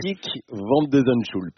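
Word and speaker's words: Botic van de Zandschulp: Botik van de Zandschulp. – Vous l'avez vraiment très bien dit. Oui, vous le dites mieux que Rinderknecht.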